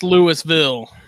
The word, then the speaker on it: Louisville: And it's pronounced Louisville.